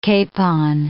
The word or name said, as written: capon